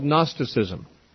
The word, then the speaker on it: Gnosticism: That was called Gnosticism, spelled with a G at the beginning.